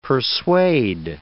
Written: persuade

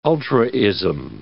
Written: ultraism

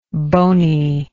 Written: boniness

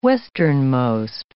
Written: westernmost

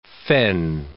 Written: fen

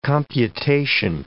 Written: computation

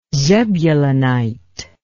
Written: zebulunite

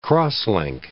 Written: cross-link

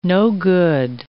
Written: no-good

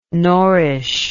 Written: norrish

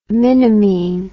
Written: minimine

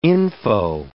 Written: info

